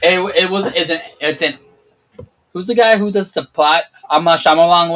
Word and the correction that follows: M. Night Shyamalan: whatever his name is. I butchered his name. – Yeah, M. Night Shyamalan.